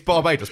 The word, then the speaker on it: Barbados: They don't get to choose how you pronounce a country's name, surely. It's Barbados. People in Barbados would say, it's Barbados.